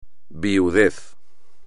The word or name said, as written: viudez